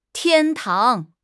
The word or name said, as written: 天堂